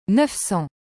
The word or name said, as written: 900